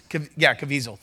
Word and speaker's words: Caviezel: I listened to Jim Cavuto, who played, I think I'm getting that name right. What is it? Who played Jesus. Caviezel, thank you. Caviezel, I knew I was saying that wrong.